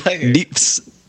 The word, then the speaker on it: neeps: That's the spell that. Wait, nips? N-I-P-S? – N-E-E-P-S. Nips is a Scottish word for turnip, and then tatties is a Scottish word for potatoes.